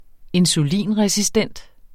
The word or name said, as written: insulinresistent